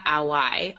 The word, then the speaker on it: Auwai: We have Autumn Saunders, Maya Young, and Tamika Awai.